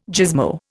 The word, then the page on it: gizmo: Indian